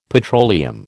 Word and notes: petroleum: American English

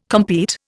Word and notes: compete: Indian

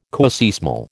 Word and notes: coseismal: American English

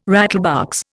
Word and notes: rattlebox: Indian

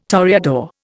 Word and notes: toreador: Indian

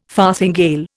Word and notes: farthingale: Indian

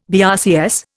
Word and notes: BRCS: Indian

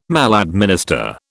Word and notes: maladminister: British English

